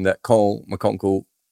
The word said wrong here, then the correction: McConkie: is it McConkle or McConkie? I thought it was McConkie. Have I got his name wrong? How many Coles? Yeah, McConkie. Scared me there, Josh.